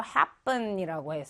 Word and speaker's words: happen: 그래서 여기 pp라는 거는 뭐 우리 발음으로 할때 happen 이렇게 피읖 발음으로 하지 않고 쌍비읍 발음이 난다라고 생각하면 돼요. 그래서 happen이라고 해서 일어나다, 발생하다라는 거고요.